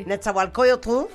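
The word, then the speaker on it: Netzahualcóyotl: Netzahualcoyotl, lo dije bien, sí. – Netzahualcoyotl, con un concierto espectacular presentando el disco Estirpe, eh, con la Orquesta Sinfónica de Minería.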